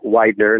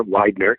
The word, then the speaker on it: Widener: But you can just search my name, Chris Widener, and it's spelled W-I-D-E, like the word wide with a ner on the end, N-E-R, Widener.